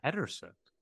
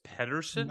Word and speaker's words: Pederson: Pedersen. – That's my. That is my sister-in-law's parents. – They spell it Peterson, but it's Pederson. – No, it's with a D.